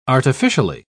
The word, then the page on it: artificially: British English